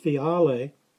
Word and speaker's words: phiale: Both words come from the Greek P-H-I-A-L-E, pronounced fiale,